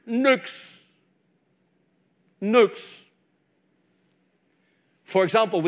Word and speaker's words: Nyx: And if you were spelling it in the English language now, it would be the letters N-Y-X. But it's pronounced Nux. N-O-O-X. Nux was the father of Moros. And when you look at the word night, N-I-G-H-T,